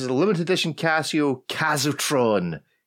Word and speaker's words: Casiotron: Yeah, Casiotron.